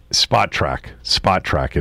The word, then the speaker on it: Spotrac: Spotrac is how you pronounce it.